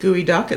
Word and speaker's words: geoduck: And for those of you who are not from the Pacific Northwest or just not familiar with gooey duck, it's spelled G-E-O-D-U-C-K.